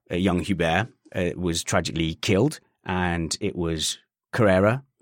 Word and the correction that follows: Correa: Juan Manuel Correa.